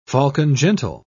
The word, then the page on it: falcon-gentle: British English